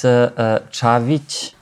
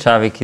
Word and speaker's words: Cavic: No, actually, it's Cavic. – Cavic. – But yeah, it's hard to pronounce, so. – That's great. – Cavic is fine as well.